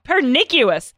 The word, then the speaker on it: pernicious: Pernicious. Injurious. – Pernicious? – What? How do you pronounce pernicious? Pernicious. – Pernicious. I don't even know that word.